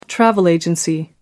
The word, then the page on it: travel agency: American English